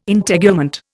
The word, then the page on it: Integument: Indian